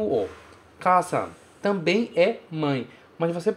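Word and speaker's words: okasan (お母さん): E a pronúncia dele é okasan. Okasan, que quer dizer mãe.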